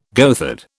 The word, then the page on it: goatherd: British English